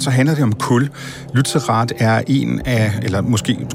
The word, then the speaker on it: Lützerath: Det er øh, Lützerath. Er det sådan, man udtaler det? – Det er nemlig rigtigt, ja.